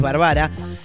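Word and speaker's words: barbara: El hurón mayor, Eira Bárbara o Eira Bárbara, no tiene acento en la primera A, no es esdrújula, así que supongo que es Bárbara.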